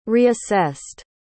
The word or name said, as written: reassessed